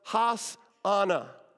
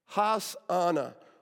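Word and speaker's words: Hosanna: That word save us would have been in Hebrew pronounced has ana. Has ana.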